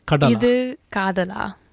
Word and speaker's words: Ithu Kadala: This is called, how would you pronounce it? Ithu Kadala. – Ithu Kadala.